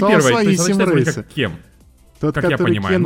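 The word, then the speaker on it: Cem: Э, ну, то есть, короче, читается либо кем, либо чем. Вот я не знаю. Кем чем, я, я не знаю. А, кем чем? – Нет, вот кем.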